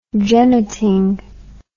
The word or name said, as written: jenneting